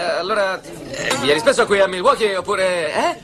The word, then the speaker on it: Milwaukee: A dire il vero si pronuncia Milwaukee, che in Algonquian significa la buona terra.